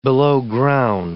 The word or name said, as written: belowground